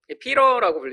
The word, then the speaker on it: Peter: 우리 베드로를 영어로 뭐라고 부르죠? 피러라고 부르죠 피러. 한국 발음은 피터고요. 원어 발음으로는 피러. 피러.